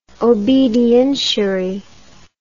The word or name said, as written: obedientiary